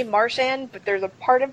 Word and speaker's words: Marchand: Because he'd actually, you know, talks to him and he's like, yeah, Brad's like, no, I'm from Nova Scotia, we don't pronounce it right. It's Marchand.